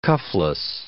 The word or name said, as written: cuffless